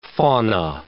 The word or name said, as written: fauna